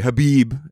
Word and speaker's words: Khabib: No, I'm sorry, Khabib is how you pronounce it.